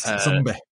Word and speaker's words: Zombi: And as you also mentioned, that was titled Zombie with a short I at the end. – Zombie.